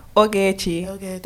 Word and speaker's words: Ogechi: So Ogechi. That's how you say it. But like when I say it in my normal British accent, it's like Ogechi.